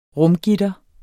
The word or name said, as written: rumgitter